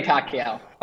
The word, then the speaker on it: Pacquiao: It's It's Manny Pacquiao.